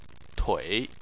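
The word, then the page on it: tui: tone 3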